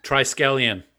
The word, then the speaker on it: Triskelion: Triskelion.